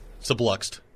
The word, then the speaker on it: subluxed: Subluxed. No surgery required. – That's a good word, and you said it correctly.